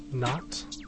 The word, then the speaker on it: Knot: Before that, we heard Arca with Not, K-N-O-T, from the album Ampersand.